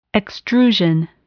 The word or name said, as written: extrusion